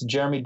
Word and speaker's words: Jeramey: The 0.01% I am on my own, it's Jeremy Dane, J-E-R-A-M-E-Y, because my mom likes to spell it unique, D-A-N-E, Jeremy Dane.